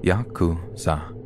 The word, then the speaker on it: Yakuza: The rules of the game are fairly complex, but the worst possible three cards to draw are 8, 9, and 3, pronounced Yakuza in Japanese.